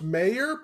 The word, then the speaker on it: Meijer: Major. He goes, it's pronounced Mayor, but he spells it with a J. It should be Major.